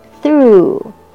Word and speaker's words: through: 下一个是 pass through, T-H-R-O-U-G-H. G-H 在这边是不发音， O-U 是发 U。Through, pass through, 他们过来的时候。Insect, insect, 所有的昆虫 like butterfly, like ants, like bees are all insect.